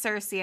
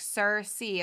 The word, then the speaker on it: Circe: Like, my name is Cersei, and so many people call me Circe, and I fucking hate it. That's not my fucking name. But I'm not going to correct it, because, like, it's literally a pronunciation thing. Like, I'll be like, yeah, my name's Cersei, and they'll be like, Circe. And I'll be like, Cersei. And they're like, that's what I'm saying, Circe. And I'm like, what the fuck ever.